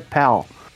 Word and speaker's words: Pal: É que a pronúncia, né? A gente tem essa dificuldade de pronunciar o L e o U, né? É tudo pronúncia de U, mas é pal. Né? Tem que dar aquela abridinha na boca para falar.